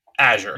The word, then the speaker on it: Azure: Do you pronounce it Azure or Azure? – Azure. – You say Azure. – Yeah, that's how I always pronounce it, is Azure. – Is it when you talk to Microsoft people, is that how they pronounce it? – That is how they pronounce it whenever I've talked to anybody up at Microsoft Corporate. – Okay. Well, that settles that.